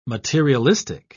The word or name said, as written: materialistic